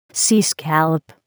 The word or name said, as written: sea scallop